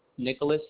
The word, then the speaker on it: Nicholas: Nicholas Jimenez.